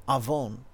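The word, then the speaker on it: avon (עָוֹן): And in Hebrew, that word is avon. That's actually spelled like Avon, right? A-V-O-N.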